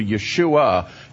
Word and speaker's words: Yeshua: So you want to make sure that you put that last syllable on there and you refer to Yeshua.